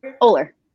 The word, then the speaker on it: Oller: Did I say his name correctly? Oller. Yep.